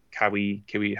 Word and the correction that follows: kawaii: however you pronounce it, face. – Kawaii face. That's right. It's like Hawaii.